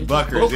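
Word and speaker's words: Bueckers: I just think it's Buckers, right? It's Buckers. Yeah. Okay. Yeah. All right. That U threw me off. Okay. Yeah. UE threw me off.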